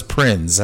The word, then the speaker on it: Prinze: I never was sure how he actually pronounced Prinze. That name. – Prinze. – It was Prinze, huh? – Yes.